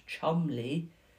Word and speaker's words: Cholmondeley: Cholmondeley Castle, which is actually spelt like it would say Cholmondeley, but it's pronounced Chumley, is a stately home and gardens which showcase Cheshire's aristocratic heritage.